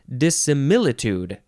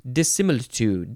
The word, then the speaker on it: dissimilitude: Dissimilitude. You got to emphasize the mi, and then the last syllable can be tued or tued. Dissimilitude.